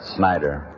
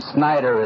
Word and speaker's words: Snyder: As you heard, my name is Clarence Snyder, and I come from St. Petersburg. For the benefit of anyone here who belongs to the press, the radio, or the films, that name Snyder is spelled S-N-Y-D-E-R.